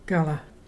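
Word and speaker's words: garla: Gala, that's fire. And to spell that, there's G-A-R-L-A.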